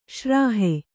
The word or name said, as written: ಶ್ರಾಹೆ